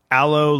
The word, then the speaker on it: Allo: Allo and Duo, which I'm told it's pronounced Allo like aloe vera, not like hello.